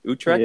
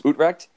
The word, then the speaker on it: Utrecht: It's Utrecht. – Utrecht. Yeah. – Yes. Utrecht.